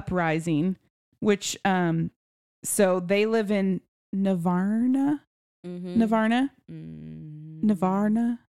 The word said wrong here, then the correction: Navarre: That was Navarre. About pronunciation? – Navarre. Yeah. Okay.